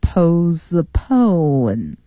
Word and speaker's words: postpone: 嗯，也就是这个 t 是不发音的，它原来是 postpone 啊，是两部分组成，但是呢，第一个部分里边的 t 不发音，所以呢，它这个单词念成 postpone，延期、推迟的意思。